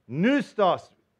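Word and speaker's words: pneustos: Noustos, spelt with a P. How many know any words that start with a P but sound like N?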